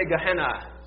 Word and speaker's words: Gehenna: Everybody say Gehenna.